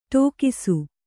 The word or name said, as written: ಟಕ್ಕಿಸು